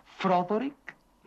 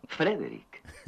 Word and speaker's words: Frederick: ¿Dice usted también Froderick? No, Frederick. ¿Y por qué no es Froderick Frankenstein? ¿Por qué no? Es Frederick Frankenstein.